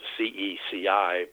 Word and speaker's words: Ceci: And of course, uh, my work um, was really preceded by Dr. Stephen Ceci, that's spelled C E C I, but uh, pronounced like Whitney Houston's mother, Ceci.